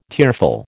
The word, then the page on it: tearful: American English